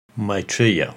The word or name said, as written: Maitreya